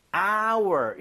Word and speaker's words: hour: H는 자음이면 어잖아요. – 하지만 묵음이죠. – 그죠. 이게 hour 묵음이 아니라 네. hour 이렇게 발음이 되는 거예요. – 아, 발음 자체가 모음 사운드예요.